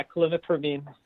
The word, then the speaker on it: clomipramine: You're gonna have to help me with the pronunciation here, but the next question is please talk about the benefits of clomipramine. – Good. Yeah. – Yeah. Is that right? – Uh-huh. Okay. Yeah, that's right. Yeah, clomipramine.